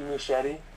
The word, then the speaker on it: Muschietti: Is that how you pronounce it? – Yeah, I think it's Andy Muschietti.